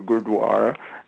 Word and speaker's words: gurdwara: That was a very very well done with the pronunciation of gurdwara, so commend you on that, actually.